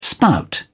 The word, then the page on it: spout: British English